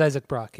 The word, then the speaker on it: Brock: It's Isaac Brock, yeah. Yeah. – Brock like Lesnar. – Sorry. Yeah, or like Brock from Pokemon. – I was thinking about other Brock that I know.